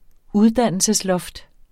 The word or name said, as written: uddannelsesloft